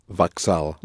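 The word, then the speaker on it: вокзал: Ja jedu na vokzal. – Da, jedu, pronounce it accenting the first vowel. Ja jedu na vokzal, da.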